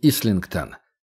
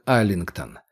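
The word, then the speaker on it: Islington: А вот и Ислингтон. Раньше, когда он только читал про Лондон, он думал, что это слово читается как Айлингтон. С таким же нарушением правил, как слово «Исленд», «Айленд».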